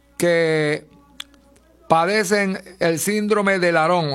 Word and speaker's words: Laron: Se llama el síndrome de Laron, Laron, Laron, así como lo estoy pronunciando.